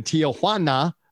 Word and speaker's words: Tijuana: I cannot have you on and not ask you a little bit about the atmosphere in Tijuana.